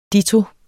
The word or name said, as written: ditto